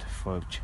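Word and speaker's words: Fàilte: Scots Gaelic for welcome, pronounced Felch.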